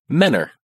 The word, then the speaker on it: Mentor: The word mentor? – See, that's a town. That's a part of the Cleveland area. We pronounce it Menor.